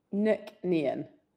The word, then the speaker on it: McNean: How do you say it? – McNean. – McNean. Okay. – Yeah. You probably Nick never like, get that question. – Nick like Mook and then Nean. Very simple. Or Neen if you really want to say it quickly.